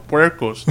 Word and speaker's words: puerco: We should be feral puercos, dude.